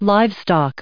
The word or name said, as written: livestock